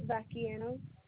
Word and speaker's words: Vacchiano: It was Vacchiano. With a V. – Can you spell that? – V-A-C-C-H-I-A-N-O.